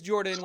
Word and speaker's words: Jordan: My name is Jordan with a silent ph in the middle, and with me, as always, is my co-host Sir Lucian over there at Sir Lucian Gaming, live from Gamehole Con.